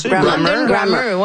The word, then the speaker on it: London Grammar: En l'écoute de Bonne Nuit, chérie et Soleil Secret, j'ai pensé à un groupe qui s'appelle London Grammar. Je prononce bien? – Grammar. – London Grammar, ouais.